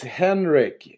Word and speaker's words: Henrik: It's Henrik. It's the it's the German version of Henry.